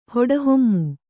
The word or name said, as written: ಹೊಡಹೊಮ್ಮು